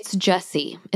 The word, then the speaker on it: Gyasi: Okay, so my first book is Transcendent Kingdom by Ya Gyasi. I think I pronounced that right. I'm not sure. – I did look it up. It's Jesse.